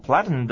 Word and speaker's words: platinum: 再来就是他说说那个白金卡 ,platinum car,platinum 哈 ,platinum 这个字呢，它那个 TI 很有一种哈可能消掉的倾向。像美国人念快这个字呢，他念作 platinum, 他念作 platinum,platinum, 那个 T 呢就吃在喉咙里面这样的声音，我们念作 platinum, 来这里念一下。 – platinum,platinum 这样的念法。